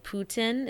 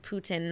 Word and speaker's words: poutine: I actually also really like poutine. It's pronounced poutine, not poutine.